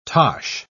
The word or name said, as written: tosh